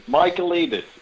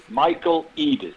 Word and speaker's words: Michaelides: Michaelides. Michaelides. All one word.